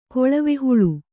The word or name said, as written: ಕೊಳವೆಹುಳು